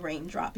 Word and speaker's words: Reigndrop: So people just started calling me Reigndrop because I was little. – Yes. – And I just decided to spell it R-E-I-G-N instead of R-A-I-N because it had more meaning.